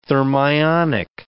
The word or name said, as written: thermionic